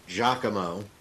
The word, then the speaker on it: Giacomo: All right, not to correct you, Droopy, but it's Giacomo. That's how that name is spelled.